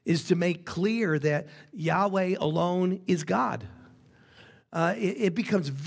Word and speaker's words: Yahweh: and it's represented by four Hebrew consonants, YHYH, which is, and I think rightly pronounced, Yahweh.